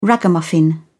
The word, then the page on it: ragamuffin: British English